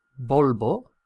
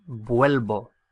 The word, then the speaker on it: vuelvo: It should be volvo, V-O-L-V-O, volvo. But there's a lot of pressure on that syllable with the O. Vol. And it gets stressed out. And it stresses out to the extent that it turns into UE. So I return is vuelvo, vuelvo, V-U-E-L-V-O, vuelvo.